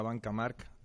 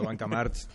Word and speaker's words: Banca March: El banco se llama Banca Mark y yo he tardado un año en saber pronunciarlo y me he pasado un año pronunciando Banca March, pero se llama Banca Mark y me cuesta todavía pronunciarlo bien.